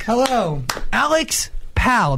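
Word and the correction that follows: Pall: Did I say that correct? – No. Alex Paul.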